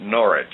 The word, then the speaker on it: Norwich: Northwich, England. The British pronounce it Norwich.